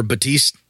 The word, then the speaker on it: Baptiste: A little more Baptiste. The P is silent. – Baptiste. I don't know. I've heard it pronounced other ways. – Batista? – Batiste.